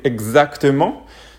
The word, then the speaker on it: exactement: Donc exactement, c'est euh, l'équivalent en anglais de exactly, exactly, donc exactement. Si je dois un petit peu euh, expliquer la prononciation, c'est egg, egg, un petit peu, c'est un petit peu un son comme, comme ça. Exact, exactement, ex, egg, c'est un petit peu, voilà, comme ça.